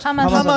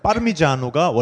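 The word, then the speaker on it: Parmigiano: 파마산, 여러분들 파마산, 파마산 이라고 알고 있지만은 그게 원래는 파르미지아노가 원래 발음입니다.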